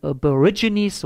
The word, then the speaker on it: Aborigines: Bei den Aborigines, so muss man es aussprechen, es, wird es Regenbogenschlange genannt.